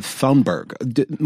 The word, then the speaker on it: Thunberg: Thunberg. – Yeah. – Thunberg. – B-E-R-G in Swedish isn't Berg. It's pronounced like it's a fruit. Berry. – Yeah. – Like you're getting a berry or something.